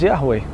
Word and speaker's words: Yahweh: In fact, there are many names for God in Judaism, the most prominent of which is the Tetragrammaton, the four-letter expression YHWH, pronounced by some as Yahweh and declared to be too sacred to be pronounced by many rabbis.